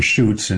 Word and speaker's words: Schutz: They can also just type Ron Schutz, and it's spelled S-C-H-U-T-Z, S-C-H-U-T-Z. I got to make a song about that. There's no L in my name.